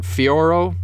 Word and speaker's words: Fiorot: Manon Fiorot? I know you don't pronounce the T.